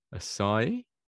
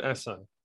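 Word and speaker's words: Assaye: So, you know, I've never actually heard it said properly myself, weirdly enough. You don't actually hear it said very often, but I say it Assai. – Yeah. Assai.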